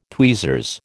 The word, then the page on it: tweezers: American English